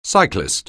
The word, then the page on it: cyclist: anglais